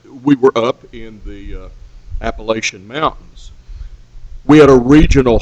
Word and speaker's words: Appalachian: I had to learn to pronounce it Appalachian.